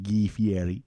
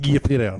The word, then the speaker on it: Fieri: and Dives with Guy Fieri. They have delicious... – I believe it's pronounced Guy Fieri.